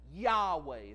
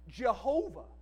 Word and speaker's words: Yahweh: YHWH. And it is from that where we get the name of God. Yahweh is how it's usually pronounced. We might even be even more familiar with kind of the anglicized version of that, Jehovah.